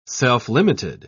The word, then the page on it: self-limited: British English